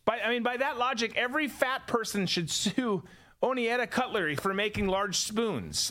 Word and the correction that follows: Oneida: Like, or is it Oneida? Oneida. Yeah. I forget how you say it.